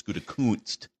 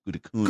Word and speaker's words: Gutekunst: firing Gudakun? She's got to be careful the way you pronounce that with the FCC lurking over uh, ESPN Milwaukee. It's Gutekunst. Gutekunst.